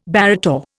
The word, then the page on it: barrator: Indian